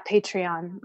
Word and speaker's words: Patreon: Yeah, Patreon.